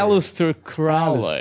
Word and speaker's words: Aleister Crowley: Eu te Alistair Crowley? Eu é lembro que é assim? – Ou Aleister? Alistair. Alistair Crowley.